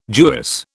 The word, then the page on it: Jewess: British English